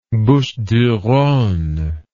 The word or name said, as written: Bouches du Rhone